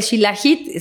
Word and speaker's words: Shilajit: ¿Y el Shilajit, dijiste? – Ajá, el Shilajit se escribe S-H-I-L-A-J-I-T.